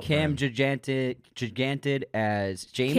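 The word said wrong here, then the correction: Gigandet: Cam Gigunde. – Gigunde. I've never said that out loud. That's how it's said.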